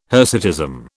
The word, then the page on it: hirsutism: British English